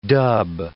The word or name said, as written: dub